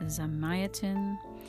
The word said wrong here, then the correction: Zamyatin: um, Zamyatin.